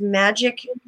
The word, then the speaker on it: Majik: If you look at, it's Magic LLC on Facebook, and that's spelled just like my name, M-A-J-I-K.